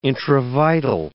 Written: intravital

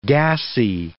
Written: gassy

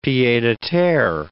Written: pieds-a-terre

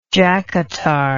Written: jackatar

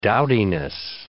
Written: dowdiness

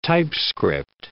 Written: typescript

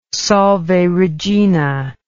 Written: Salve Regina

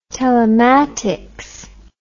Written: telematics

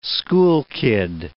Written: schoolkid

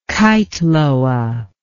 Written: keitloa

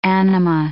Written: anima

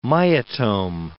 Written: myotome

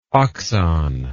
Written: oxon

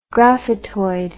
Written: graphitoid

